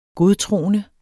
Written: godtroende

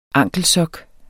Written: ankelsok

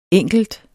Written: enkelt

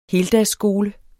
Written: heldagsskole

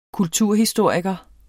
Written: kulturhistoriker

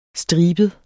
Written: stribet

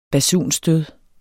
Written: basunstød

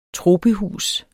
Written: tropehus

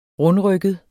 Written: rundrygget

